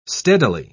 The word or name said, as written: steadily